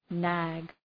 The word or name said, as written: nag